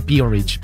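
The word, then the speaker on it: P-Orridge: Y se pronuncia Peerage.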